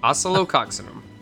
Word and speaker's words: Oscillococcinum: Oscillococcinum.